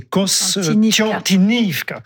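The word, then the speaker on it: Kostiantynivka: Alors, la russe, c'est Konstantinovka, c'est ça. Et l'ukrainienne, c'est Konstantinivka. Donc voilà, alors bon, j'ai la vieille habitude, je suis désolé, mes jeunes années en Russie.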